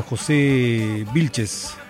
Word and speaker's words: José Vilches: Este saludo va hasta Venezuela, José Vilches. Sí, se pronuncia así, bien, está.